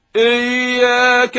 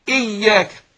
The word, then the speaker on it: إياك: إي، للأسف كثير من الناس بينطق الكسرة هنا بيميلها فيقول إيه، يعني ينطقها زي الحرف الإيه الإيه، يقول إيه إياك نع إياك إيه، لا انطقها زي الحرف الإي، أقول إياك. يبقى ابين الايه الكسر اللي في الهمزه فاقول اياك، والياء مشدده فاقول اياك.